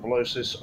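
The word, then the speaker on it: Velocys: It's VLOCYS is how it's spelled, Velocis.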